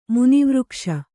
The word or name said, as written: ಮುನಿವೃಕ್ಷ